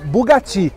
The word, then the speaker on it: Bugatti: E aqui do lado, pessoal, tem um Bugatti ou Bugatti, que é a pronúncia certa.